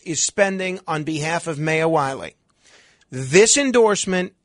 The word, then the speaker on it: Maya Wiley: I always thought it was Maya Wiley, but I'll go along with the AOC pronunciation and assume it's Maya Wiley.